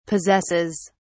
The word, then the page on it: possesses: British English